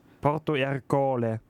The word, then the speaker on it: Porto Ercole: 1608 flieht er quasi von Malta und 1610 stirbt er in Porto Ercole. – Also in irgendeiner Hafenstadt höchstwahrscheinlich. – Ja. – Ja. – Willst du nochmal die Aussprache kontrollieren? – Stimmt. Alles gut. – Porto Ercole.